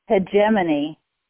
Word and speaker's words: hegemony: Now, some people are going to pronounce that word hegemony, and it's spelled H-E-G-E-M-O-N-Y. So it's pronounced either hegemony or hegemony, depending on where in the world you live.